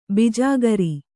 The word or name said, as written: ಬಿಜಾಗರಿ